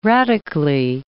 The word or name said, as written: radically